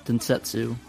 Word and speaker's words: Densetsu: Kondo Saisoku Densetsu. And hopefully I said that right.